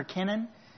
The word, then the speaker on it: Kenan: Then you have Canaan or Kenan, as it's pronounced by some.